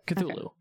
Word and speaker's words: Cthulhu: Yeah, Cthulhu.